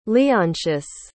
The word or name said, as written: leontius